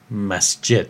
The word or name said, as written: Masjed